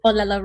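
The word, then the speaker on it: Olelo (ʻŌlelo): And at El Lati, there's the, I think I'm saying this right, Olelo room? – Olelo room, yeah.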